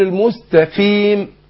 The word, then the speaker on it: المستقيم: يبقى ما هوش مستقيم ط، انطق التاء ط، لا المست والقاف مكسورة تاخد ادنى درجات تفخيم، اقول المستقيم. صراط